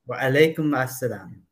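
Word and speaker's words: Wa alaikum assalam (وعليكم السلام): That's Walaikum al salam. Did I say it right? – Wa alaikum assalam.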